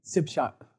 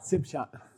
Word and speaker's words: Sipshak: So say Sipshak. Sipshak. The spelling is Tsishak and the colloquial is Sipshak because they pronounce the prefix letter of the second syllable.